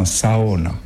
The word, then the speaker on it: sauna: En finnois, bien sûr, on appelle ça un sauna. – On le sait maintenant, on prononce toutes les lettres.